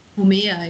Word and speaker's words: Homer: Und das ist ja auch so ein sehr episches Werk, das von Homer, wird das so ausgesprochen? – Homer, ja.